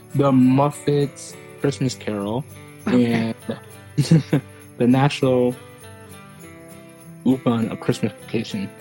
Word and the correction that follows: Lampoon: National Lampoon.